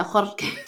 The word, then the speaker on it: Jorge: George Delgado, spelled J-O-R-G-E, George. – Oh. – George Delgado. And he was a personal trainer. And he was actually the manager, Jorge.